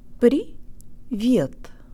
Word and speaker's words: привет: При вет.